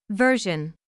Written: version

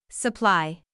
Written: supply

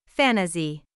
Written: fantasy

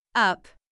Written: up

